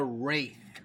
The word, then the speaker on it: Wraith: Ugh, it's called The Wraith with a W first. – Yeah, so spell it. Spell it for the people. – W-R-A-I-T-H. Wraith.